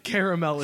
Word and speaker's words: caramel: I still stand by that caramel is the correct pronunciation of this food.